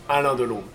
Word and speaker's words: Alain Delon: Alain Delon. How do I really say his name? – Alain Delon. – Beautiful.